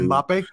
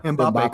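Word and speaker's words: Mbappé: They offered, I think it's Mbappe, I think I pronounced his name right, soccer dude. – Mbappe, yeah, Mbappe, Kylian Mbappe.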